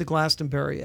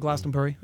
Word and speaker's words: Glastonbury: But the festivals, I mean, as somebody said here last week, they said, if you haven't been to Glastonbury yet, or Glastonbury, I got to say it properly, you know, you haven't lived.